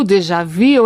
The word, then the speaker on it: déjà vu: E qual que é a pronúncia francesa legítima? É déjà vu, déjà vu ou não é nenhum dos dois? Conta pra gente. – É muito difícil reproduzir, mas é déjà vu.